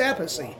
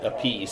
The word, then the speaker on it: Appice: He's like, no, I'm not going to call it Appice. It's not Appice. It's Appice. – Yeah, Carmine pronounced it Appice. – Because it just fit better. People thought it was Appice. It was easier.